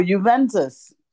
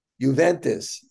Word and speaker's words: Juventus: Oh, Juventus. – Juventus.